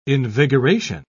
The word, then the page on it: invigoration: British English